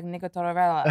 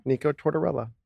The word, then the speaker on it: Tortorella: I'm for sure saying it wrong, but it's not. I'm saying it right the whole time. – Nico Tortorella.